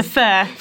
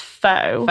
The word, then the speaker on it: pho: It's spelled pho. – Yes. – But so, why is it pronounced fur? – Well, so a lot of people, and I did as well, think it's pronounced faux. – Faux, yeah. And lots of other words, pronunciations as well, but it is fur, we can show you.